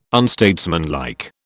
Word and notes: unstatesmanlike: British English

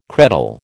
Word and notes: credal: American English

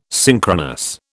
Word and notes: synchronous: British English

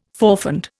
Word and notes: forfend: Indian

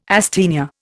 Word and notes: asthenia: Indian

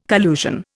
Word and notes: collusion: Indian

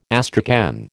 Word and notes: astrakhan: American English

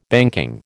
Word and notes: banking: American English

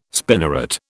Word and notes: spinneret: British English

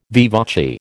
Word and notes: vivace: American English

